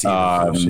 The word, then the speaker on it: Eberflus: Matt Eberflus, is that how you say his name? I'm not quite sure. – It is. It's Eberflus, yes.